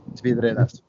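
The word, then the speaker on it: Vidreres: Estamos en ese pueblo, lo pronunciaré mal, pero bueno, Vidrieres o como lo queramos llamar, ¿no? Eh, es que no sé cómo se pronuncia bien. Vidreras. Vidreras, vidreras.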